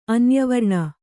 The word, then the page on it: ಅನ್ಯವರ್ಣ: kannada